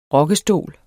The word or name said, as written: rokkestol